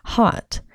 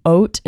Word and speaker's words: haute: therefore it's more hot. And that is pronounced hot in English, but haute in French, but I speak American English, so it's hot for us.